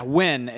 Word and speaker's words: Nguyen: Yeah, Nguyen is how you pronounce his Nguyen.